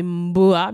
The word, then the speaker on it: Mboa: Et Omboa, qui se prononce en réalité Mboa, mais moi, je l'ai un peu remixé.